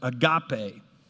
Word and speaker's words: agape: And the word that he uses for love there is an ancient Greek word pronounced agape.